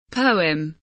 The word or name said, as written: poem